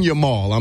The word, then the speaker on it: Yamal: I'm definitely, I tell you who's still in the show is Yamin. Was it Yamin? Is how you say his last name? – Lamine Yamin, yeah. Lamine Yamal, I'm sorry.